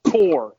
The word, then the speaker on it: Coeur: You unleashed a debuting 3-year-old filly by Macho Uno named Core. Am I pronouncing it C-O-E-U-R? – Yeah, it's if you're French, it means it core. But I say core.